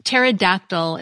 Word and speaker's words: pterodactyl: Since it's the ptosis part that means to fall, and it's pronounced without the P, even though it's spelled with a P, P-T-O-S-I-S, Kerr suggested that his discovery should be pronounced without the P, apoptosis. Pterodactyl is another word you may be more familiar with that has that P-T combination where the T isn't pronounced. It's spelled P-T-E-R at the beginning, but pronounced pterodactyl.